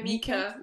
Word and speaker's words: Mika: like not like the Bee Gees or like Micah does. Mm-hmm. Or Mika, I don't know how you pronounce it. – Oh yeah, Mika.